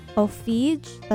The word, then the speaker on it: Ofege: Right before that was It's Not Easy by Ofege, that's spelled O-F-E-G-E.